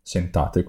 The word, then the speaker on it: scentate: Molto spesso vengono scentate, che significa aromatizzate, da scent in inglese, sentate quindi, la pronuncia giusta,